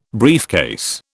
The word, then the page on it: briefcase: British English